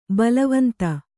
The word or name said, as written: ಬಲವಂತ